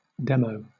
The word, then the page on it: demo: British English